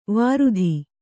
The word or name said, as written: ವಿರೋಧ